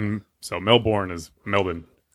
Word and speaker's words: Melbourne: Before we get too far, I don't know if we have a lot of Australian listeners, but the name of the city is pronounced Melbourne. – What? – Not Melbourne.